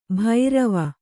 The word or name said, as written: ಭೈರವಿ